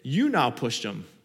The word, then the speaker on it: Utnapishtim: The hero's name is spelled UTNAPISHTIM. I didn't know how to pronounce that. I looked it up. It's called, you pronounce it, you now pushed him. Something like that, close.